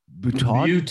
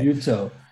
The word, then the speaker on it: Butaud: Butaud. – Yeah. Okay. – Butaud.